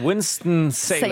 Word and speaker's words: Winston-Salem: Erstmal die Frage, habe ich das richtig ausgesprochen? – Absolut, Winston-Salem. Salem.